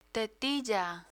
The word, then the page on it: tetilla: spanish